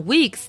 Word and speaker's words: Whigs: 这个辉格党 Whigs 的发音跟假发很像，对不对？假发 W I G Wig。